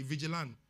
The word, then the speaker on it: vigilant: It's vigilant. Without a T, vigilant. It's silent T. If the T is there, why do you silence it?